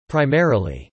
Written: primarily

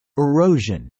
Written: erosion

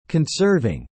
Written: conserving

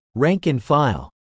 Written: rank and file

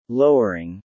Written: lowering

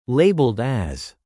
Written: labelled as

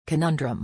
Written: conundrum

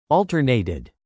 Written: alternated

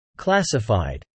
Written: classified

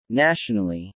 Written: nationally

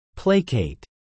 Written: placate

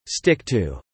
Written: stick to

